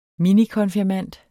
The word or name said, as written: minikonfirmand